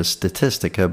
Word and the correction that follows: Statista: but I believe the website is pronounced Statista.